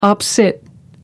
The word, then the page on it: op cit: American English